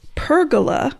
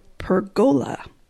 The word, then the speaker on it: pergola: Lumbago, L-U-M-B-A-G-O, lumbago. – Correct. All right. Dan, your word has two pronunciations. – Oh, boy. – One spelling. – Eat it, Dan. – It's the 21st century. Get used to it. – The word is pergola or pergola.